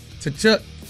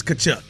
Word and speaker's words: Tkachuk: Tkachuk. I think that T is Tkachuk. You're right. That T is silent. it is Keith Tkachuk.